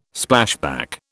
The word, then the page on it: Splashback: British English